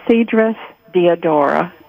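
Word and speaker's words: Cedrus deodara: I have a Cedrus deodora Christmas tree. Am I pronouncing that correctly? – You sure are.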